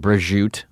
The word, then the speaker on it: Breguet: I believe Swatch Group owns Breguet now. – Yeah. – Which is spelled Brejute. This is some French shit.